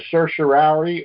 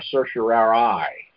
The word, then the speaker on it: certiorari: Yeah, that's a certiorari or a certiorari, however you want to say it. – That's how you say it? Okay. Yeah, I tried. – Or certiorari.